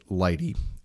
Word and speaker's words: lighttpd: Lighty is spelled L-I-G-H-T-T-P-D, and so they pronounce it Lighty, and it's a much smaller piece of software.